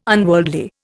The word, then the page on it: unworldly: Indian